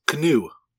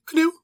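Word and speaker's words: kniv: There's a K before an N, and they pronounce it. Just like English, we used to pronounce the K, and we don't anymore. Now it's knife, it's just knife. There, it's still canoe, canoe.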